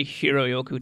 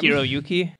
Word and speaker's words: Hiroyuki: Yeah, the guy who played Shang Tsung, the villain, is Kerry Hiroyoku Tagawa. – It's a great name. – Hiroyuki.